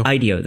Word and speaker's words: IDEO: IDEO. That's always been a like, like IDEO with an O at the end.